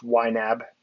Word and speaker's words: YNAB: I think it's more commonly known by its acronym, which is pronounced YNAB.